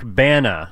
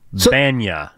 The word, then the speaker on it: Bana: Like the actor Eric Bana. – Oh. – It's not Eric Banya.